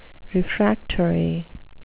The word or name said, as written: refractory